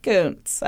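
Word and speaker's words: Kuntz: It's spelled like cunts, guys. But it's pronounced Koontz, I swear.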